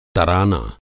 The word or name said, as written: तराना